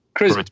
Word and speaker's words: Kreuzberg: How do you say Kreuzberg? – Kreuzberg? Kreuzberg. – Kreuzberg. Yeah, it's in Kreuzberg.